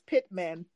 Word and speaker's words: Pittman: My last name is Pittman, P-I-T as in Tom, T as in Tom, M-A-N.